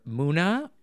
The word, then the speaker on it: MUNA: And also, I don't know how to pronounce this name, but it's either Muna. – Yep, that's it.